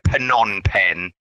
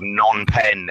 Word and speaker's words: Phnom Penh: People might call it a Phnom Penh, which is the capital of Cambodia. It's the wrong pronunciation, and it's called Phnom Penh. The P is like silent.